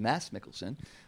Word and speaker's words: Mads Mikkelsen: That was Mads Mikkelsen, who the actual pronunciation is, Bill Bregoli tells me, is Mads Mikkelsen, from the movie Arctic, which you're going to talk about in a moment.